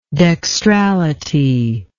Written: dextrality

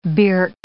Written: birk

